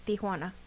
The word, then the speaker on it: Tijuana: Tijuana. Let me say that right before somebody comes at me.